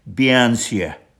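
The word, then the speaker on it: bean sí: The correct pronunciation of this female spirit is Bianse,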